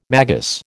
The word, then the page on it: magus: American English